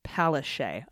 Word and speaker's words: Palaszczuk: Anastasia Palazikuk, pronounced Palaszczuk. Okay, totally off base there, Palaszczuk. – That was awesome, though, that there was a correction right after you were like Palazikuk. – Palazikuk. Nope, Palaszczuk.